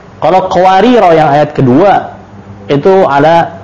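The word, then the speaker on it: qawarira (قواريرا): Sedangkan yang sebelumnya itu cara bacanya dua ya, ada dua, dia ketika disukunkan bacanya mad, qawarira, qawarira, dibaca panjang ketika disukunkan. Kalau diwasal baru bacanya pendek.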